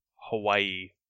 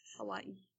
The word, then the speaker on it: Hawaii: Hawaii. Yeah. – Although if you really want Hawaii to pronounce it correctly, you should pronounce the W as a V, because that's how it's pronounced in Hawaiian.